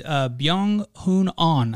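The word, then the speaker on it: Byeong Hun An: That is Sungjae and Byung-hoon On, I believe, right? Mm-hmm. I think is how you pronounce that. So my apologies if I butchered that. – I think that. – You absolutely nailed it.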